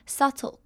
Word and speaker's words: subtle: 最后我们要来看的是 subtle 这个字，subtle 的 b 不发音，不要把它念出来，所以就是直接 subtle 过去。